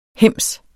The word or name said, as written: hems